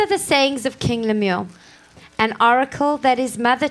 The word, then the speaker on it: Lemuel: Lemuel, am I saying it right? – Lemuel, yeah.